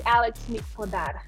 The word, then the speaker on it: Alex Mic-Podar: You're doing better than most people. It's Alex Mic-Podar.